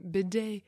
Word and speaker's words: bidet: But obviously it originated in French, the term bidet. So I don't know if that's how it's supposed to be said, but you don't pronounce the T like I was doing for all my life.